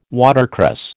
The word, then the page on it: watercress: American English